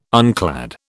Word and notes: unclad: British English

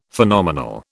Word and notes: Phenomenal: British English

